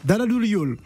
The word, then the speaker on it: Daradoul Yol: Julia Sarr se déploie en solo hein, depuis son album euh, Dara Daradoul. Je ne sais pas si j'ai bien prononcé. Dara, Yol. – Daradoul Yol. C'est en quoi C'est en Wolof.